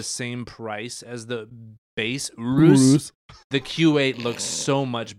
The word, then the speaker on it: Urus: You have to say it like all guttural. Urus.